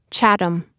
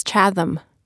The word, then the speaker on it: Chatham: During the course of recording my audiobook, I realized I had trouble pronouncing Chatham. Apparently, my whole life I had been pronouncing that word wrong, as Chatham.